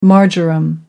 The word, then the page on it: marjoram: American English